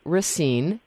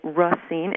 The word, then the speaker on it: Racine: I'm from Racine. Some people say Racine, others say Racine. I say Racine, even though I speak French and know the correct pronunciation is Racine.